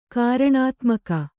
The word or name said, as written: ಕಾರಣಾತ್ಮಕ